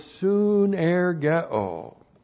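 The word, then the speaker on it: sunergeo: And then we have the verb soon ergo. S-U-N-E-R-G-E, long O. Soon ergo.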